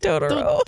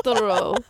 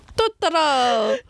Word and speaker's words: Turturro: Why am I trying to say it like Totoro? – Turturro. Turturro.